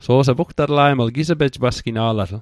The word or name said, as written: pasgina'latl